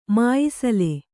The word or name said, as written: ಮಾಯಿಸಲೆ